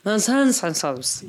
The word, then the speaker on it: Menzies: Tobias Menzies. Am I saying that right? – No, it's technically pronounced Menzies.